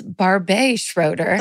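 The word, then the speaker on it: Barbet Schroeder: I incorrectly pronounced their name, Barbette Schroeder, and declared them to be a woman and said I was really proud that my favorite movie was directed by a woman. It is absolutely not a woman, Barbette, and it's actually pronounced Barbe Schroeder.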